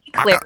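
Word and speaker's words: éclair: What is an eclair? Is that an internet clair? – You don't know what an eclair is? – It's an eclair. Like, you're putting the emphasis on the wrong syllable.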